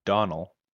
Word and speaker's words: Domhnall: And fun fact, Domhnall Gleeson's name is pronounced Donnell.